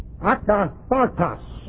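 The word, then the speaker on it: akathartos (ἀκάθαρτος): It is akathartos, A-K-A-T-H-A-R-T-O-S. And the emphasis is on the second A, though it's hard to pronounce. I'd like to put it on the third A. A-K-A-T-H-A-R-T-O-S.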